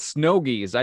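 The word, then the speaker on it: Snowgies: The reason why they're called snogies, I just figured this out. It rhymes with bogey. Like snow boogers.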